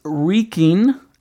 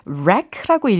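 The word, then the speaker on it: wreak: it's reeking havoc.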